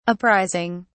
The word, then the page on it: apprising: British English